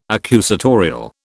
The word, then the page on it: accusatorial: British English